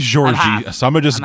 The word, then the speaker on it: Jorge: And it's around this point where we get introduced to, I believe I'm pronouncing it right, Georgi. – It could be George or Jorge, I don't know. – I believe it's Georgi, because I think I double-checked this. In Brazilian Portuguese, J-O-R-G-E is pronounced Georgi.